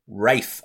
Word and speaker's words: Ralph: Like I think Ralph Fiennes is a perfect choice for Ramses because he's got Rafe, I believe his his his n- name is pronounced.